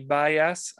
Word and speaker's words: Bias: I guess we have Sarah Neely and Courtney Bias.